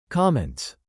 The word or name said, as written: comments